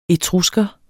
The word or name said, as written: etrusker